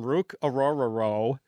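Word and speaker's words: Ruke Orhorhoro: It's Rook Ororo. That's how you pronounce his name, Rook Ororo of Clemson.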